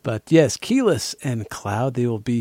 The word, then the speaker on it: Quiles: And yes, I admit it, I've been mispronouncing her name for all this time. I was saying Quiles, because it is spelled Q-U-I-L-E-S.